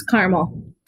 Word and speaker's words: Carmel: Lance Design, a full-service, family-owned interior design firm based out of, is it Carmel, Indiana? – It's Carmel. – Carmel, Indiana. There you go. I knew I'd mispronounced something.